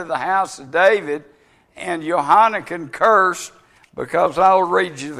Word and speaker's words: Jehoiakim: but by the time of the evil king Jehoiakim is how it's pronounced,